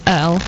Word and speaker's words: Earl: Even though it's not hard net, it's my surname is Earl, which is E-A-R-L, but I'm not even lying.